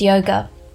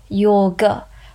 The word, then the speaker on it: yoga: How many times have you heard the phrase decolonize yoga? The correct pronunciation, by the way, is yoga, or casually pronounced yog.